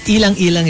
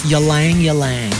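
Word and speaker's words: ylang-ylang: In for Americans, ilang-ilang is yalang-yalang.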